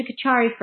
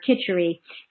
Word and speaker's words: kichari: So there's a kachari for every dosha, or kichari, as it's often pronounced.